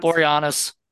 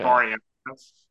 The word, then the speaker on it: Boreanaz: Boreanaz. – Yeah, it was called These Girls. – David Okay. – Boreanaz.